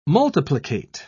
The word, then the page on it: multiplicate: British English